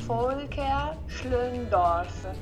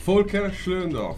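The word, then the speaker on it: Volker Schlöndorff: Volker Schlöndorf.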